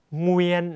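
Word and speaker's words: Nguyễn: อะอูเวียนเงวียนต่อมาเอามานยุกซักมาใส่ด้วยนะครับเงวียนซักเงวียนเงวียนเหวียนเงวียนเงวียนหอยเงวียนเงวียนหงาเงวียนนะครับสังเกตเงวียนก็คือนามสกุลของคนเวียดนามนั่นเองนะฮะต่อมาเงวียนหนังเงวียนนะครับเสียงสั้นเงวียน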